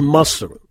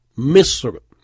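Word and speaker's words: Misr (مصر): Alors, souvent, les Arabes disent Masr, Masr ou Masr, etc. Donc, il faut respecter la prononciation officielle, Misr.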